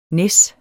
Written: næs

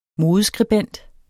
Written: modeskribent

